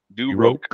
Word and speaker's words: de Roque: You pronounce it R-O-K-E. DeRook. – DeRook. – It's just a K. It's a Q-U-E on the end is French, and you pronounce it as just like you would a K.